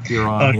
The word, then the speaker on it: Euron: But, like, this season when was it Euron Greyjoy? Is that right? Am I pronouncing that right? – Euron, yeah.